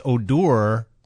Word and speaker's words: Odor: Again, it's pronounced Odor... But even the announcers didn't say anything about it during the game because I would have pointed out it's spelled Odor, but it's not. It's Odor.